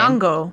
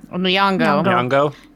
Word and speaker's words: Nyong'o: Nyong'o. – Nyong'o.